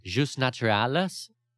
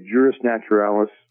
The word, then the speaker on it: juris naturalis: But I guess to start off, in your books you explain about, and I'm going to try to pronounce it, jus naturalis. Is that how you say it? – No, I say it juris naturalis.